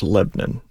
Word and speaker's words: Lebanon: I would argue that one's actually two syllables. Lebanon.